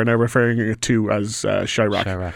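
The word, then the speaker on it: Chi-Raq: So the one you're going to start with tonight is Chirac. Am I pronouncing that correctly? – You are, Chirac. It's portmanteau between Chicago and Iraq,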